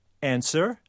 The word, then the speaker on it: answer: Or answer. – W.